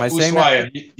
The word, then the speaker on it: Ushuaia: Yeah, Ushuaia. You don't say the H.